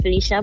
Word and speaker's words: Felicia: I say it Felicia, but you can say it, you know, I like to say it however I like, to add a bit of pizzazz.